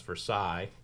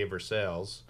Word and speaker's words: Versailles: Versailles is spelled the same as Versailles in France. – But you but say it. – A, we say Versailles.